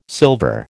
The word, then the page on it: silver: American English